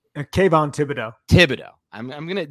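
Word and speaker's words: Kayvon Thibodeaux: Kayvon Thibodeau.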